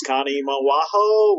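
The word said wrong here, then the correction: Conemaugh: Where the hell were you guys from? – Kanema. – There you go.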